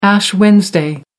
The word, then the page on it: ash wednesday: American English